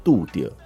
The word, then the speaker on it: 渡對: Do right，杜对的台语发音就是杜点，就是相遇的意思。而英文其实也蛮有趣，如果你直接的感受或直接的翻译，大概就会是叫做做对的事。我真的觉得杜点